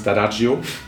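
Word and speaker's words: D'Addario: Ob das jetzt Ernie Ball ist, ob das Dadario ist. Der Pro sagt übrigens Dadagio. – Dadagio? – Dadagio, genau. Können auch viele nicht aussprechen. Dann Dadagio. Italiener, ganz klar.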